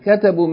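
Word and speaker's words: katabu (كتبوا): And katabu means they wrote. It is pronounced katabu without any consideration of the aleph that follows the waw. In other words, it's as though the aleph is not there.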